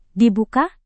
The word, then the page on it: dibuka: indonesian